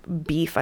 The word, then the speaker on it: beef: They still got the boaf. That's just beef. I don't know why I pronounced it like that.